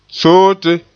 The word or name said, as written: soote